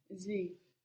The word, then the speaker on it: Sie: Sie. – This is spelled S. I-E. So we get the S again making a Z sound and the I-E just making an E sound.